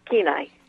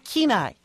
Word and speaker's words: Kenai: Is that correct pronunciation? – It's Kenai. – Kenai.